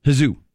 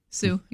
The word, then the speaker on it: Hsu: I don't think you'd pronounce the H in H-S-U. Sue.